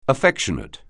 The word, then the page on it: affectionate: anglais